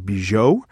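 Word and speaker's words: Bejo: But we do have a clip of the stars of the artist, Bernice, I think she pronounces her name, Bijot, and Jean Dujardin, talking about living in Hollywood in the 1920s as their characters, Pepe Miller and George Valentin.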